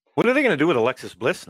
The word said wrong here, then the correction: Alexa Bliss: now? Alexa Bliss. Do I keep saying it wrong? – You said it right a couple times in there. – Yeah. Alexa Bliss.